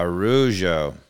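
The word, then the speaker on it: Araújo: It's basically air. Ojo, Ejo, and the emphasis Arujo. is on the O, but. Uh, um, uh, Arujo.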